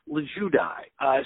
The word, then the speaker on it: Lulgjuraj: From the Tots Company, Susan Lulgjuraj.